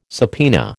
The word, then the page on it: subpoena: American English